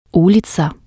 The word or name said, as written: uliza